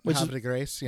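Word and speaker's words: Havre de Grace: Now, the title of the episode is, I'm going to mispronounce this, I imagine, Harvey de Grace? Can you pronounce? – That's pretty good, yeah. Okay, that's it? Harvey de Grace? – Yeah, Harvey de Grace, yeah.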